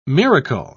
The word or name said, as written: miracle